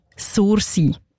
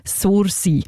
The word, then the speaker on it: Sursee: Einmal so aussprechen, wie ein Einheimischer oder ein Einheimischer das würde. Und ich komme ja selber auch von dort, darum zeige ich es euch jetzt schnell mal vor. Sursee. Nicht anders, Sursee.